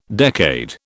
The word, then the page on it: decade: British English